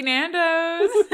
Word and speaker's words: Nando's: Let's go to cheeky Nando's. – No. Did I say that right? – You did, yeah.